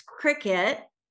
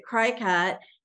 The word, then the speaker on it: Cricut: C-R-I-C-U-T. – Either cricket or cry-cut. – Yeah, exactly. And it's cricket. I, for years, pronounced it cry-cut until I started working with the queen of cricket and realized, oh, it's the same word.